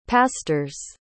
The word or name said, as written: pastor's